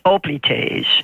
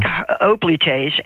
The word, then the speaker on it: hoplites: There's a term for them when you see them with the helmet and the spear and the shield, hoplites, H-O-P-L-I-T-E-S, which in Greek would would be pronounced hoplites, but in English people say hoplites, hoplite warriors.